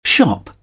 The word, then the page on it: shop: British English